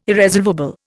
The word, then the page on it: irresolvable: Indian